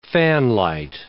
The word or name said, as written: fanlight